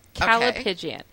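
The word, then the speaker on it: callipygian: It's C-A-L-L-I-P-Y-G-I-A-N. Calipigian.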